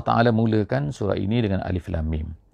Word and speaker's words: Alif Lam Mim: Alif lam mim, bukannya alam. Ada di tempat-tempat yang lain dalam Al-Quran ini, kadang-kadang alam tara kaifa fa'ala, alam tu maknanya lain. Alam tu ejaannya sama, alif lam mim, tapi bacaannya alam.